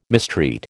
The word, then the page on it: mistreat: American English